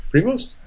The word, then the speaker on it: Prevost: Prevost. – It's spelled P-R-E-V-O-S-T. – Oh, okay. – Prevost. I would say Prevost.